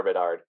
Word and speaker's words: Bedard: Connor Bedard.